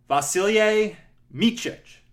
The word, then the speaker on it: Vasilije Micic: Vasilije Micic.